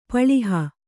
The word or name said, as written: ಪಳಿಹ